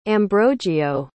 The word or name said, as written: ambrogio